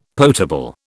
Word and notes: potable: British English

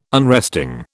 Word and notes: unresting: British English

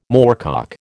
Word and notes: moorcock: American English

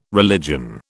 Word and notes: religion: British English

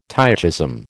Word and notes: tychism: American English

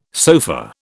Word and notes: sofa: British English

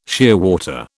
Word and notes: shearwater: British English

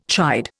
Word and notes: chide: Indian

